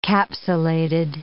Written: capsulated

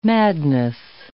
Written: madness

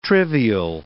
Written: trivial

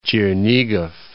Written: Chernigov

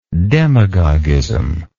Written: demagoguism

